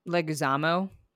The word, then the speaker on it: Leguizamo: But I have a shout out for John Leguizamo.